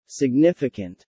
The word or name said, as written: significant